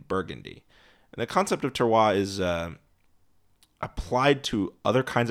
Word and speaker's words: terroir: called terroir, which claims that the land, I mean, that's spelled like terror, but with an I after the O. So it's T-E-R-R-O-I-R, terroir,